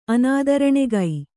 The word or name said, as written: ಅನಾದರಣೆಗೈ